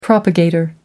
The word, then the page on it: propagator: American English